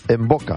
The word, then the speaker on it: Mvoca: Atentos que necesitan o quieren recuperar el brillo de su sonrisa gracias a Envoca Estética Dental. Envoca se escribe M-V-O-C-A. En boca, me siento como Village People. En boca, tiri, tiri, tiri, es en boca.